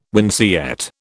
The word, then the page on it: winceyette: British English